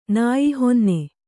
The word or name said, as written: ನಾಯಿಹೊನ್ನೆ